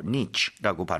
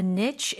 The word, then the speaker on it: niche: 자, 그리고 niche라고도 발음할 수 있고 niche라고 발음할 수도 있는 거 바로 틈새, 특정 영역을 얘기를 하죠. – The brand carved out a niche in the luxury skincare market.